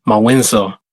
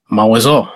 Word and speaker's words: Mawinzo: So for instance, in the word Mawinzo, he said everybody would just pronounce it way too hard and it would sound like Mawinzo. He's like, Mawizo. You get that Mawinzo, like a nasal Z in there.